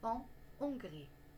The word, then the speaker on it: en Hongrie: Le H dans Hongrie n'est pas muet. Donc, on ne fait pas la liaison avec le N et on prononce en Hongrie.